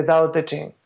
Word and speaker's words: Tao Te Ching: The Tao Te Ching is spelled with a T, T-A-O-T-E, Ching, but it sounds, we pronounce it with a D, Tao Te Ching.